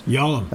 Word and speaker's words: Yalam: Tell us about Kamala and about, I hope I say this right, Yalam's? – Yalam. – Is that, yep, I'm saying that right? Again? – Yep, that works. – Yalam.